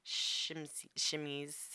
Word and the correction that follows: chemises: how do you say that? Yeah, chemises.